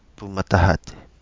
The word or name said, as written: Pumatahati